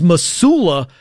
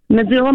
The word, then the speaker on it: Missoula: But also a little bit of it's a hard S, not a soft S. – Missoula. – Correct. – I got it. Missoula, Montana. – It's like a Z.